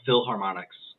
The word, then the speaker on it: Philharmonics: Philip Harmonica, whatever the fuck it's called. How do you say it? – It was, let me go back here. It was, Philharmonics.